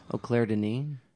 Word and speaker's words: Claire Denis: Some Clar Dennis. – Yeah, it's a guy, right? Clar Dennis. – Oh, Claire Denis. – That's how it's pronounced.